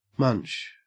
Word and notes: munch: Australian English

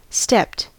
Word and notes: stepped: American English

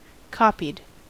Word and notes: copied: American English

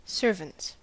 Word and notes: servants: American English

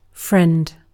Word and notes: friend: British English

